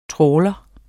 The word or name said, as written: Trawleren